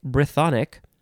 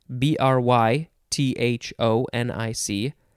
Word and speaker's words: Brythonic: Uh, how do you say it? Brythonic, B R Y T H O N I C, Brythonic, but specifically the Welsh language, I guess, is also called Cymric.